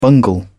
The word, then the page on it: bungle: British English